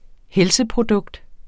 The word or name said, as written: helseprodukt